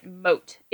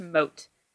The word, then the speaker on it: Moth: So with Don Armado, there's his servant, Mote. It is spelled Moth, M-O-T-H, but it is pronounced Mote.